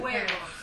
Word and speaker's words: huevos: Jueves. And huevos. – Our yeah. H is silent.